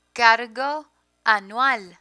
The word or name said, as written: cargo anual